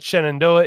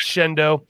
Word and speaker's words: Shenandoah: Um, if you're from Pennsylvania, you know it's not Shenandoah, it's Shendo.